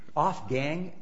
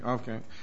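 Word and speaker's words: Ofgang: Or Off-Gang, O-F-G-A-N-G, I believe. – Okay, Off-Gang.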